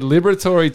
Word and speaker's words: Liberatore: Liberatore.